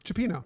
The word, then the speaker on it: cioppino: is a fish stew called cioppino. – Cioppino. – Cioppino. Yeah. And I think you've actually told me the story of kind of how that stew, fish stew, originated. – Yeah, so essentially Cioppino, it is a fish stew of a variety of different fishes.